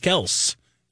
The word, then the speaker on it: Kelce: You may also remember last year, I believe it was our buddy who we just talked about before, Travis Kelce, who decided, no, it's Kels. You've been saying it wrong for years.